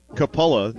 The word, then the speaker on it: Coppola: It's spelled C-O-P-P-O-L-A. But it's pronounced Capola, not Coppola.